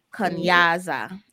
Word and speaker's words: Kunyaza: You have what we just mentioned, Kanyaza, which is spelled K-U-N-Y-A-Z-A. Kanyaza or Kunyaza.